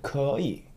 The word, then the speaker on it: 可以: 可以. Could you see? There's two syllables. 可以. E. E, even though there's like Y-I, that's still like Y is considered a consonant in Chinese.